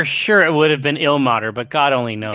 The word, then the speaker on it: Ilmater: Ilmater? Am I pronouncing that right? – Yeah.